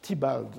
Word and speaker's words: Theobald: Par Mr. Theobald, je crois qu'on prononce Theobald en anglais selon Peter Stalybras,